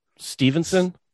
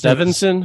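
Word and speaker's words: Steveson: Gable Stevenson. – Yeah, but it's his last Stevenson. – Name. Yeah, it's weird. – Stevenson. – St like. Evesson or something like that.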